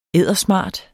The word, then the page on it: eddersmart: ˈεðˀʌˈ-; ˈeðˀʌˈ-